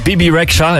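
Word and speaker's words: Bebe Rexha: Bibi Rexha. En zo spreek je dat dus uit.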